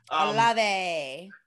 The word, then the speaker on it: Olave: You got Chris, I'm going to say, right, Olave, is that how you say it? – Olave.